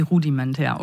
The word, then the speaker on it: Rudimentär: Du arbeitest mit dem DJ und Beat-Produzenten, spricht man es rudimentär? Ah, okay, wie rudimentär, okay, verstehe.